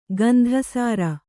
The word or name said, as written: ಗಂಧಸಾರ